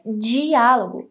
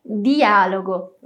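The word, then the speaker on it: dialogo: Então, não poderíamos dizer diálogo, como essa pronúncia que usamos em grande parte do Brasil. Vamos dizer apenas diálogo, tá vendo, né?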